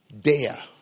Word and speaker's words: deah: In the Hebrew, it is dea, that is D-E-A-H, and sakal. S-A-K-A-L. I'm not an expert in Hebrew, so this is my best Afro-British pronunciation I can come up with.